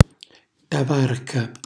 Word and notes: tavarca: Calabrese

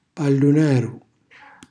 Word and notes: pallunaru: calabrese

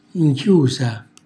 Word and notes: nchiusa: Calabrese

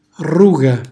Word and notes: rruga: calabrese

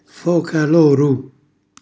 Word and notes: foramalocchiu: calabrese

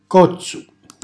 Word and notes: cozzu: calabrese

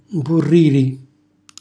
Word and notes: mpurriri: Calabrese

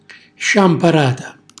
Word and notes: sciamparata: Calabrese

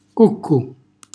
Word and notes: cuccu: calabrese

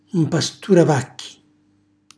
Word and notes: mpasturavacci: Calabrese

